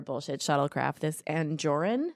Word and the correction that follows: Angosian: Yes. – Did I say it right? – Angosian. Three. I'm an Angosian three.